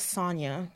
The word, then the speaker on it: Sonja: Well, my name is spelled S-O-N-J-A, but it's pronounced as Sonia because the J is, you know, pronounced as a Y.